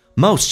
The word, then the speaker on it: most: Most 这个发音要非常的饱满，先发再发 u，嘴巴掉到极限往前推再往上，有一个弧度叫 most。 Most T letter T is silent， T 不发音，后面是以辅音开头，所以这个叫略音。